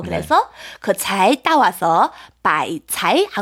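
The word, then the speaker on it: 바이차이 (白菜): 그래서 배추의 중국어는 바이차이.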